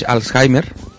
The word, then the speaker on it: Alzheimer: if the uh, word is pronounced as same as in Spanish and as also in English. Well, in Spanish, Alzheimer.